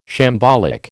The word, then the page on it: shambolic: American English